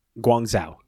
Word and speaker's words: Guangzhou: We go to Guangzhou.